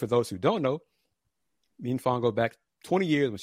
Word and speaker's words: Mfon: But after this edition of Folks Talking Sports, give me some time and I will produce my interview with his sister, Mfon, and it's Mfon. That's how it's pronounced. That's how she pronounced it. Mfon, M-F-O-N.